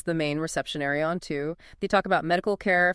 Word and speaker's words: TU: Which brings us to Planet Two, TU, pronounced two.